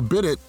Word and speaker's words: bidet: It's a bidet. It's Italian, yes. Yeah. – No. It's spelled no, it's B-I-D-E-T.